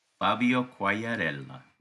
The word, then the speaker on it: Quagliarella: Fabio Quagliarella.